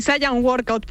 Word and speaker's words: Saiyan Workout: Sí, Saiyan Workout, pero lo puedes pronunciar como tú quieras.